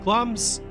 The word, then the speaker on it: Glamis: Glamis Castle, yes, the I isn't pronounced actually, is in eastern Scotland, about a 20 minute drive north from Dundee, and was supposed to have been the home of Macbeth.